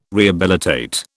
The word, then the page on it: rehabilitate: British English